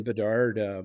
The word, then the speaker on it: Bedard: And Bedard, is it Bedard? – Yeah. – I'm a, Bedard? – Bedard, Connor Bedard.